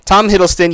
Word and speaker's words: Tom Hiddleston: I am saying his name right. Tom Hiddleston? Tom. Because I get him confused with him and Tom. I mean, there's so many Toms. Tom Hiddleston, yeah, I had it right.